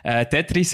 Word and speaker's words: Tetris: Tetris, habe ich Englisch ausgesprochen. Tetris war es, genau.